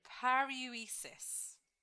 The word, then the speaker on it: paruresis: It's paruesis. – What can you spell at least that middle bit? – Paruesis. So it's spelled P A R U R E S I S.